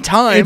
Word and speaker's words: thyme: There's but now if you spell it T-H-Y-M-E. Time as in the herb.